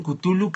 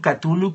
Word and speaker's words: Cthulhu: Ahí dicen Cthulhu, catulu Cthulhu, eh, Chululú.